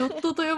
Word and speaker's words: JWT: ジョットと読むんだって JWT は。